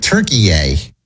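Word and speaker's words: Türkiye: Yeah, they're pronouncing it weird. Turkey-ay. Yeah. T-U-R-K-I Y-E.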